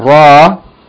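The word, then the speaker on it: Ra (ر): Okay, so this letter, lesson 27, the letter Ra, is not exactly like R in English. But rather, the Arabic Ra is pronounced by tapping or striking the tongue against the roof of the mouth.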